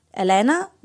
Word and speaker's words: Elena: Elena Nikolova.